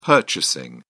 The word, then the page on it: purchasing: British English